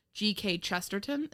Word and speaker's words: Chesterton: G.K. Chesterton. Is that how you pronounce it? – Yeah.